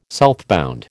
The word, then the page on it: southbound: American English